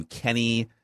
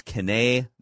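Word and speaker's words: Kene: So whoever last week, whoever the broadcaster was last week was saying Nwangu, and somebody else had a pronunciation calling him Kenny. It's Kane Nwangu is how you pronounce it.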